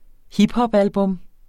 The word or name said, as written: hiphopalbum